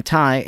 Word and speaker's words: Tighe: Even though it was posted on May 12, Tommy Tighe, handle at the GH is silent, tweeted something that seems more relevant than ever today.